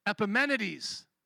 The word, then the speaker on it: Epimenides: How you pronounce his name is Epitomus. Epitomus, how would you say it? Epimenides. Okay, so Epimenides, who he's going to quote in just a moment, in him we live and move and have our being.